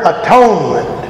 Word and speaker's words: atonement: Well, if you look at the word atonement, the central part of the word is one, O-N-E. The prefix is A-T. The suffix is ment. And you can pronounce it at one-ment.